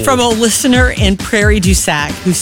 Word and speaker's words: Prairie du Sac: Few moments, we heard from our manager, Steve, who let us know that there is a woman in Prairie du Sac.